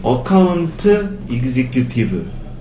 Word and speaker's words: Account Executive: AE 어카운트 이그지큐티브 맞죠? – 네. 발음 안 틀렸죠? 안 틀렸어요.